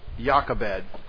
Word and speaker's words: Jochebed: I never remember how to say this right because I've seen too many movies. Jochebed. That's the right name.